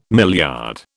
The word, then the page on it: milliard: British English